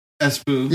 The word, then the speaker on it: Espoo: Espoo. – Yeah, like letter S and then poo.